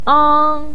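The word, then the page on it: ang1: tone 1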